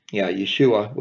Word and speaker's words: Yeshua: Yeshua would be the correct way to pronounce Jesus.